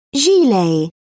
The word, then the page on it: gilet: British English